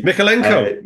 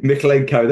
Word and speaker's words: Mykolenko: I can't even pronounce the fullback Vitali Mykolenko. – Mykolenko. There you go. I knew I could remember if someone told me. Braithwaite and then Godfrey, and then Young came on for Mykolenko after he got injured for half time.